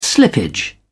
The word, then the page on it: slippage: British English